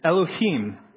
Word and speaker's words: Elohim: Elohim. Am I pronouncing that right, Brian? Sorry. Okay, good. Elohim.